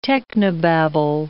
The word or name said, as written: technobabble